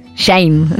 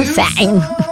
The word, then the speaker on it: sign: Tal vez dije Hollywood. Shine, ¿no? Sign.